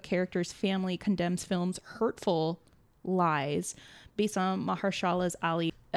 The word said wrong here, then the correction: Mahershala Ali: Apologies. So I think I butchered it. Mahershala Ali.